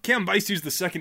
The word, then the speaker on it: Cambyses: Just because we won't get to Cambyses the second. What is Cambyses II best known for? – Cambyses II is best known for being the son of Cyrus the Great and the second emperor of the Achaemenid Persian Empire.